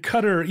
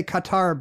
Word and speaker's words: Qatar: I feel like I used to say Qatar, but then is it closer to Qatar? Everyone says Qatar on CNN.